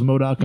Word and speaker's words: MODOK: Yeah, Marvel's MODOK on Hulu.